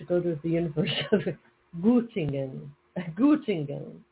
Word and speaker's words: Göttingen: But if you look at it in you know, when they do the IPA, Göttingen is how it's pronounced.